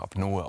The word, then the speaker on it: Apnoe: Aber Apnoe wäre, glaube ich, der offizielle Begriff, denn das kommt aus dem Griechischen Apnea. Und deswegen wird es dann eben auch Apnoe ausgesprochen offiziell.